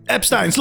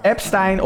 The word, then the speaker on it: Epstein: Epstein of Epstein, hoe je het ook wil zeggen. Ja, ik zeg altijd Epstein, maar dat is omdat het, uh, dat is de Nederlandse uitspraak natuurlijk. – Ja, ja, dat is waar. – Ja. – Op zijn Duits zeg je Epstein. Kan allemaal.